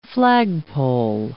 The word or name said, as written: flagpole